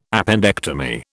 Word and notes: appendectomy: British English

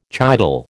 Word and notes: chital: American English

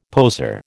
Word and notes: poseur: American English

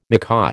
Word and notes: macaw: American English

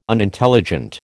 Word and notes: Unintelligent: American English

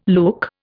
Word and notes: look: Indian

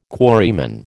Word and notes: quarryman: American English

